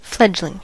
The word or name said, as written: fledgling